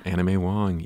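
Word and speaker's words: Anna May Wong: Anna Mae Wong. – Anna Mae Wong, yeah.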